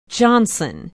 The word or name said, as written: johnson